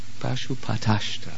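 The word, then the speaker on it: Pashupatastra: Und hol dir von und stell ihn erstmal zufrieden mit großen Entsagungen und dann hol dir die Pashupatra Ashtra. Das richtig gesagt. Pashupatashtra, sagt man.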